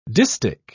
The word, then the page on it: distich: British English